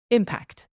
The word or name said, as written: impact